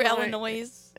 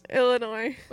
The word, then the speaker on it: Illinois: I pronounced the S. Sorry, guys. – You went through Illinois. – Illinois.